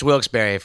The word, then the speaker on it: Wilkes-Barre: Is it I used to say Wilkes-Barre, but I think it's Wilkes-Barre, right? – It's Wilkes-Barre. If you're from Wilkes-Barre, you pronounce it Wilkes-Barre. Once in a while, you'll get someone who I don't know, went to college outside of Wilkes-Barre, came back and started pronouncing it Wilkes-Barre, and that's just not right.